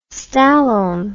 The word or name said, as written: stallone